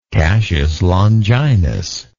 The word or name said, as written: cassius longinus